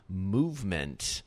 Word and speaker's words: MVMT: Jake Kasson is co-founder of Movement, but that's spelled M-V-M-T.